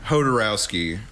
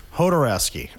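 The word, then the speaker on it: Jodorowsky: Hodorowski. – Hodorowski, okay.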